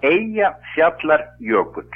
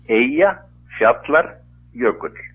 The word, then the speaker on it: Eyjafjallajökull: Então, o nome do vulcão é Eyjafjallajökull. Na peta letra, podemos traduzir isso para português. Glacier da montanha da ilha. Junta três palavras. Eyjafjallajökull.